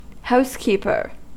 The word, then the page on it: housekeeper: American English